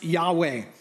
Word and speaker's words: Yahweh: You may have heard the name pronounced Yahweh.